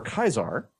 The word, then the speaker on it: Caesar: And there's, of course, Caesar or Kaisar, as he's mentioned in the game with the original pronunciation, who is a interesting character.